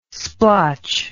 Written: splotch

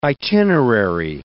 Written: itinerary

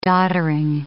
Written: doddering

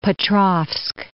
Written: Petrovsk

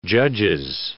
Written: Judges

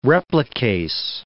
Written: replicase

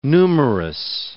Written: numerous

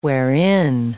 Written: wherein

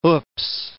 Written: oops

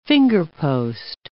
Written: fingerpost

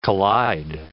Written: collide

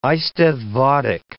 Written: eisteddfodic

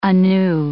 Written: anew

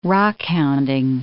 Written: rockhounding

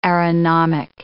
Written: aeronomic

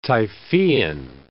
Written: Typhoean

